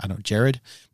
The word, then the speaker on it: Jawed: But anyway, another interesting part of this is YouTube co-founder Jared, but it's spelled J-A-W-E-D.